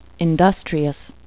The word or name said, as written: industrious